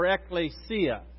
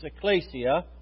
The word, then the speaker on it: ekklesia: It's ekklesia or ekklesia. The common pronunciation is ekklesia.